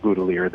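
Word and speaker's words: Boutilier: Okay, so it was originally French name. It would have been pronounced Boutelier, but the family told me that they pronounce it Boutelier. They had stopped speaking French some generations ago.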